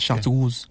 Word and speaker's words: Chartreuse: I think it's a French word. So it's pronounced Chartreuse.